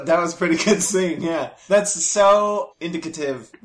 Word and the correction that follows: indicative: That's not how you pronounce that word. – Indicative. – Thank you. – The word was fine, you just dropped a syllable. Indicative.